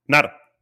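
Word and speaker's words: Gnar: Sí, Nar. Perfecto. Se le va a dar como correcta porque en el habla inglesa la G N no se convierte en ñ, no sería ñar.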